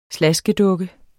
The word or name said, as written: Slaskedukke